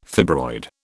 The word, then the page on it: fibroid: British English